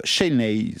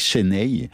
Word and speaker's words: Senay: Shenay, c'est comme ça qu'on prononce, ça s'écrit pas par exactement S-E. N-A-Y, Shenay,